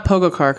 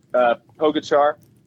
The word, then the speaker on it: Pogačar: Because it's not Pogačar, because he let us down first stage.